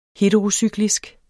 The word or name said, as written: heterocyklisk